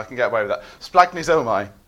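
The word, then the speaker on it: splanchnizomai: but it's pronounced something like splagnizomai.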